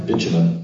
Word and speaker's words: bitumen: It is some sort of liquid that they created from, I'm going to say this wrong, bitumen, is that right? – Bitumen. – Bitumen, yeah.